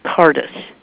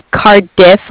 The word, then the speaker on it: Cardiff: From Cardiff. Did I say that right? – Cardiff, like car and different.